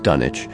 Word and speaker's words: Dunwich: It looks like Dunwich Beach, but I'm pretty sure it's pronounced Dunwich.